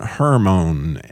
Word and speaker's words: Hermon: It's H-E-R-M-O-N, not like Herman Munster, H-E-R-M-A-N. But Hermon. And that mountain, those letters have to do with the start. You hear the R in there, that resh, R, that her, like you heard the sure, right?